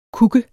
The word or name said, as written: kuk